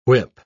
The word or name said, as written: whip